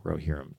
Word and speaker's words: Rohirrim: Rohirrim, that's how you say it, Rohirrim, tells the fate of the House of Helm Hammerhead.